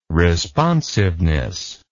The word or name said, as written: responsiveness